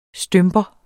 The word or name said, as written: stymper